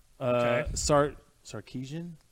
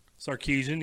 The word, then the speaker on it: Sarkisian: Sarkisian. – Sarkisian.